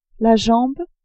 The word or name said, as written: jambe